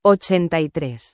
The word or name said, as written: 83